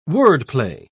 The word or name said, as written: wordplay